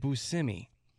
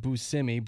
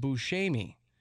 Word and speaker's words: Buscemi: He was doing a little interview for GQ magazine and he said that his name is actually Buscemi. That's how you're supposed to say it, Buscemi. But he says he's actually been pronouncing it wrong all these years too. He grew up with his name being pronounced Buscemi, but it's an Italian name, and it should actually be pronounced Buscemi.